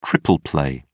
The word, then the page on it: triple play: British English